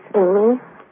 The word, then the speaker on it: Amy: My name's Amy. – Can you say your name again, please? – Amy. A-M-Y. – A-M-Y.